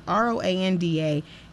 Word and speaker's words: Roanda: It's spelled R-O-A-N-D-A. Ro, I don't know, and AJ, I don't know. You know these messed up spellings. So it's Rwanda Jay Burgess.